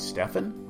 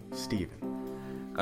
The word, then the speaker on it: Stephen: And yes, Stephen Curry has ruined the name Stephen, because now everybody tries to pronounce my name. They're like, Stephen? I'm like, no, Stephen.